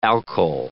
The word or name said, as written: alkyl